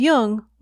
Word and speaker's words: Jung: Just be sure to pronounce Jung with a hard J.